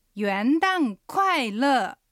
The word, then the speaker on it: 元旦快乐: 乐はラではなくてえの口の形でラと発音してみてください。ラです。では発音と合わせて言ってみましょう。 – 明けましておめでとうございます。 – 元旦快乐。